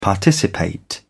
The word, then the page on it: participate: British English